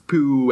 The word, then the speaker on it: Pugh: I mean, Allison already mentioned Florence Pugh, and I don't know how to say her last name, but that can't be right.